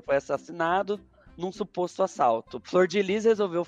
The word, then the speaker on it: Flordelis: Durante o programa, Flor, Flor de Lis, que pasme, se pronuncia Flor de Lis e não Flor Delis, como a gente comentou, tentou matar o marido sutilmente envenenando ele.